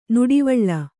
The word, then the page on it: ನಡುವಳಿ: Kannada